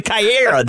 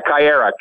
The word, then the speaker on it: Kaera: So the Caiera, that's what it is. – Yeah, Caiera, K-A-E-R-A, Caiera. So basically Caiera is the newest and latest and greatest that's come out for us at Jackal because it's a small frog.